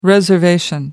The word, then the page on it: reservation: American English